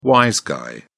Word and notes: wise guy: British English

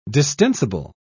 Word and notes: distensible: British English

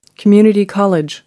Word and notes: community college: American English

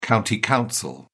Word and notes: county council: British English

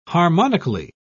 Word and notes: harmonically: British English

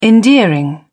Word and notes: endearing: British English

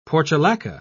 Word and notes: portulaca: British English

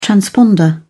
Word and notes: transponder: British English

